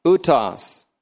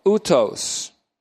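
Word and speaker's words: utos (οὕτως): Now, my first double take was that the word in this way, in this manner, utos, O-U-T-O-S, utos, or utos, that's a long O, utos.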